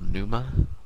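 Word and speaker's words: Pneuma: but she's revealed later to be called Numa. Panuma, it's spelled, but P is silent.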